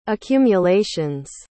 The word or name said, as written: accumulations